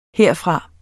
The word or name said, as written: herfra